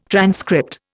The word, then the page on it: transcript: Indian